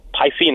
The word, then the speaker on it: Pisces: I just want to wish a happy birthday to my fellow Pisces guy, Pisces, that's how you say it, Dave Rothenberg.